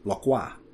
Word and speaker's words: Port Lockroy: Bransfield House formed the nucleus of Base A, established on Goodyear Island, lying in Port Lockroy, which you'll also sometimes hear me pronounce as Lockroy, because while it was named after a Scotsman, it was named after that Scotsman by Frenchmen using French pronunciation.